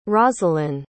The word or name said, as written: rosalyn